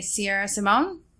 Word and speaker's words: Sierra Simone: There was one, The Misadventures in Blue by Sierra Simone. Did I say her name right? – Yes, you did.